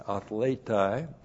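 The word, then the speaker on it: athletai (ἀθληταί): A-T-H-L, long E-T-A-I, athletai.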